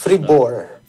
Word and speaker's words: Fribourg: Fribourg.